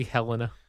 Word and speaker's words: Helena: Is that how you say it? Is it really Helena? – Yes, it is.